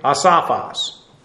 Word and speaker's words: asophos: A-S-O-P-H-O-S. Asophos, and you'll note, I understand the anglicized version of Greek. Every time people see an O, it's always a long O. Asophos. Well, that's not how it's pronounced.